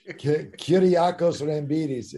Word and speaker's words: Kyriakos Rambidis: But you played in Greek under the name Kakyros Rambidis. – Yes, you did butcher it. That's exactly what you did. Kyriakos Rambidis. It's a direct translation.